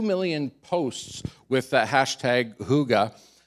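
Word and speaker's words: hygge: It's a Norwegian word, and it's pronounced "huga."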